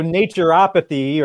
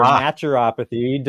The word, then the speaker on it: naturopathy: naturotherapy. By the way, what is that? – So naturopathy or naturopathy, depending on what side of the continent, at least North America, that I live on, depending on who you ask.